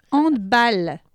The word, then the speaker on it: handball: Ensuite, handball, comme trou de balle. Parce que c'est un mot allemand et pas anglais.